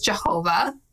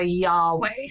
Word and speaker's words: Yahweh: Originally, we thought it was Jehovah. – Yeah, for a long time, English scholars were committed to Jehovah. – And now we think it's closer to Yahweh.